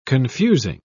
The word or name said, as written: confusing